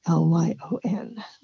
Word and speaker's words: Lyon: But remember that spelling, Meadowlyon, because my name is spelled Lyon.